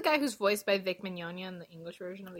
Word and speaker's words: Mignogna: Vic Mignogna.